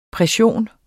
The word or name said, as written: PRESSIONS